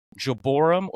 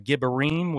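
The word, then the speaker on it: gibborim: These were the mighty men, which is in the Hebrew, Jeborim or Gibberim, which is the correct pronunciation in the Hebrew, but depending on where you're at in the world, you can say whatever you want. Jeborim is also a vernacular that is commonly used too.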